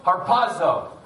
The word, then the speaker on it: harpazo: Harpazo. I think you have to say it like in Italian.